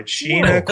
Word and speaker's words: Sheena: Sheena, Queen of the Jungle. – Rhymes with Tina.